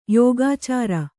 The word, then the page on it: ಯೋಗಾಚಾರ: yōgācāra